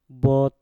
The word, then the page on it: bot: mpur